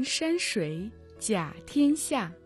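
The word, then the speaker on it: Шуй (水): Шуй – вода. В третьем тоне.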